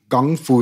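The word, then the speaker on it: Gongfu: Und jetzt um dieses Gang Fu, deswegen haben die es Kung Fu geschrieben, weil die es ja Englisch aussprechen wollten. Kung Fu, Gang Fu sagen die Chinesen, ich bin kein Chinese, aber ich habe äh, Kung Fu studiert.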